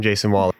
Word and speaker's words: Jasen Wahler: Well, first of all, I'm Jason Waller. I'm on the Secretary of State for ASCSU. My name is spelled J-A-S-E-N-W-A-H-L-E-R.